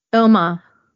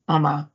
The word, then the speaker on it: Omagh: Omagh. Omagh.